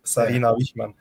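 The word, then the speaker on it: Sarina Wiegman: And I think now they've, like, obviously Serena Wiegmann. Yeah, why am I even pronouncing that as if I don't speak Dutch? Like, I really pronounce that like in English, but it's like Serena Wiegmann.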